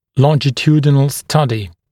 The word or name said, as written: longitudinal study